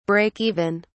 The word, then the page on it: break even: British English